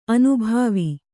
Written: ಅನುಭವ

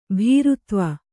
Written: ಭೀರುತ್ವ

